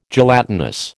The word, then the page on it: gelatinous: American English